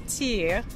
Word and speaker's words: tire: One which is pronounced or spelled T-I-R-E, which is tea, or basically maple taffy.